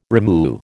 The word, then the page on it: rimu: American English